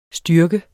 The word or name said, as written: styrke